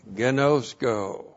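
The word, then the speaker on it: ginosko: And then we have gnosko. G-I-N-O-S-K, long O. Gnosko.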